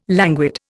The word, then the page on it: languid: Indian